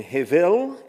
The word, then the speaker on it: hevel: The Hebrew word is hebel, or I guess the correct pronunciation, hevel.